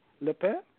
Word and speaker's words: Le Pen: Le Pen.